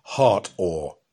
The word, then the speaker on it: Hathor: It was called Hartor, spelt H-A-T-H-O-R, but pronounced Hartor, and named after the Egyptian goddess of love and joy of the sky and of the west.